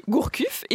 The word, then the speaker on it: Gourcuff: Sinon, on peut noter que Gourcuff est encore blessé. – Ça, c'est bien prononcé.